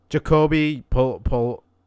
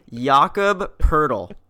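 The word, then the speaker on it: Jakob Pöltl: The correct pronunciation is Jakob Poeltl. – Jakob Poeltl. – No. – Nice. – No. No. No. Jakob. Jakob with a Y.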